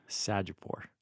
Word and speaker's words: Sajjadpour: Most people just do it phonetic. They go Sajjadpour.